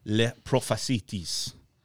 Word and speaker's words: Les Prophéties: Nostradamus, a 16th century French astrologer, is best known for his book, Les Prophéties. I'm sure I butchered that. – That sounds exactly like it's supposed to be.